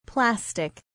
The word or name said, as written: plastic